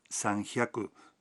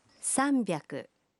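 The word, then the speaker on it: sanbyaku (さんびゃく): 300 no se pronuncia sanhyaku, sino sanbyaku.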